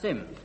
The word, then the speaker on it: Simms: It's spelt S-I-M-M-S, but pronounced Sims.